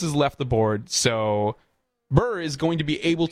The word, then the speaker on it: Buhr: Paul Burr, I believe is how it's pronounced, last name, B-U-H-R, Burr of Viroqua, to replace William Bruins, whose term expired at the end of 2022.